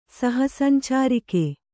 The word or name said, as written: ಸಹಸಂಚಾರಿಕೆ